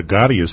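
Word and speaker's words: Gadhia: But if somebody is listening to you this morning, Dr. Smith, Dr. Anita Gaudia Smith, by the way, Gaudia is spelt, we practiced this on the way over.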